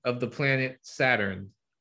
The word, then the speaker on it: Saturn: Saturn is two syllables. It's Saturn.